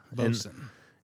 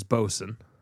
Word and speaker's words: boatswain: Bosun. – It's spelled B-O-W-A, or it's spelled B-O-A-T-S-W-A-I-N, which is Bosun, which is what their can says.